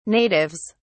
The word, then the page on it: natives: British English